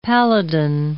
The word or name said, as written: paladin